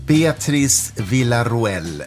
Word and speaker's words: Beatriz Villaroel: Beatrice Villaruel, välkommen till Fritankes podd. Tack så mycket. Var det hyfsat korrekt uttalat?